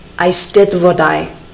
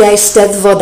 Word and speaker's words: eisteddfodau: I was little when I learned the word eisteddfod, and a little older when I learned the plural eisteddfodau. The final syllable is pronounced to rhyme with I. Those two D's in the middle are a TH sound like the, eisteddfod.